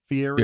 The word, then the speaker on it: Fieri: So, for example, is it Fieri? Is that how you say his name? Fieri, yeah, Guy Fieri.